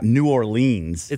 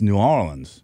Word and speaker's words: New Orleans: And New Orleans is not New Orleans. – It's not? – It's New Orleans.